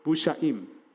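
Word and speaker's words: busha'im (מושעים): With one set of vowels, the pronunciation, the combination of this word is it is busha'im, those who have been saved.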